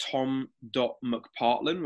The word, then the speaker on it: Tom McPartlan: They can follow me two places on Instagram, The Athlete System, or just tom.mcpartlan, which is spelled MCPARTLAN.